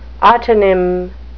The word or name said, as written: autonym